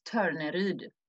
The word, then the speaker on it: Torneryd: I have not yet met a person who's not Swedish who can pronounce my name the way we say it in Sweden, but it's Torneryd.